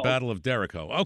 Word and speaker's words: derecho: Could you please explain the difference between a microburst and a derecho? – Derecho. Oh, okay. – Did I say it wrong? – We've said those. Remember, we've talked about those, the derechos? – Oh, derecho. I thought it was derecho.